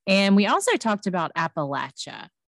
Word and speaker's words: Appalachia: And I wanted to tell you that I've seen a few posts lately with t-shirts and things talking about how to pronounce Appalachia. And it's like, it spells out and like puts the syllables on there, like Appalachia, so people can say it. And I was like, we were right about that one.